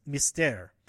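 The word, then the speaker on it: Mystère: I, I already mispronounced Mystere, Mis- Mystere. Um, seven o'clock show of Love, and then Mystere at um, uh, 9.30.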